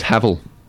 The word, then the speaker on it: Haval: I heard it was Havel. – Is it really? – Rhymes with gravel.